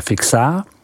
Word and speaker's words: Fixin: Dnes se vydáme na toulky vinařským regionem Côte d'Enouilh, vyslovko d'Enouilh, jejíž severní hranici tvoří vinařská obec Fixin, vyslovujeme Fixá, a jižní hranici město Dijon, vyslovujeme Dijon.